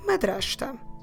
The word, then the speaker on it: madrasta: So the word is madrasta. Madrasta. Madrasta. Let me spell it for you. M A D R A S T A.